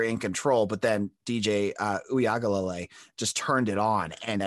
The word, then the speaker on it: Uiagalelei: So I found the pronunciation guide so I didn't sound like a complete caveman trying to botch his name up. It's Uiagalelei. – Uiagalelei.